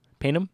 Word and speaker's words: Payneham: Payneham? Okay. The city of Payneham, formerly the district council of Stepney, was a local government area in South Australia from 1867 to 1997.